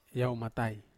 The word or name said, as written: matai